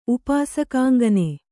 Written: ಉಪಾಸಕಾಂಗನೆ

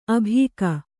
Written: ಆಭೋಗ

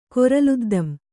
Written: ಕೊರಲುದ್ದಂ

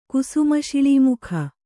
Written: ಕುಸುಮಶಿಳೀಮುಖ

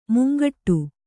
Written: ಮುಂಗಡೆ

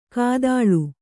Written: ಘಾತಾಳಿ

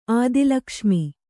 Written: ಆದಿಲಕ್ಷ್ಮಿ

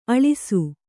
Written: ಅಲಸು

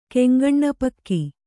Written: ಕೆಂಗಣ್ಣಪಕ್ಕಿ

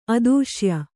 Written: ಅತ್ತಿಶಯ

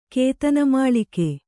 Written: ಕೇತನಮಾಳಿಕೆ

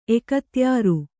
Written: ಎಕತ್ಯಾರು

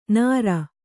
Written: ನಾರಿ

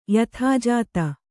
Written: ಯಾದೋಜಾತಿ

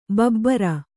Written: ಭೂಭಾರ

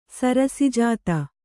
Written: ಸರಸಿಜಾತ

